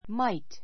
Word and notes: might: 弱形 mait; マイト; 強形 máit

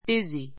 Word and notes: busy: bízi; ビズィ

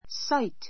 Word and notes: sight: sáit; サイト

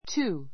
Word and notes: two: túː; トゥー

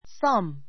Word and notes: thumb: θʌ́m; さム

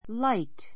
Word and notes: light: láit; らイト

